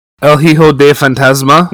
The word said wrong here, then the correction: El Hijo del Fantasma: Need to look at it. El Hijo del Fantasma.